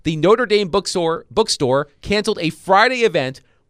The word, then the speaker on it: Notre Dame: But they did take the step of canceling the book signing, apparently because the author of a book about a Notre Dame coach was on the CFP committee.